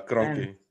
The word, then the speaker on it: Kroenke: I know Gio and yourself, mostly Gio, has been crying for how do you say it? Kroenke, no? Is that, I don't know how you say his name. – Yeah, Kroenke.